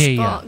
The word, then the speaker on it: Gaia: Gaia po. – Gaia talaga. It's like yung parang Earth. Earth ba? – Yes Gaia. po, Goddess Gaia.